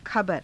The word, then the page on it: khabara: Hindi